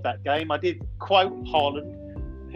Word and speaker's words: Haaland: And got off to a great start with Erling Haaland.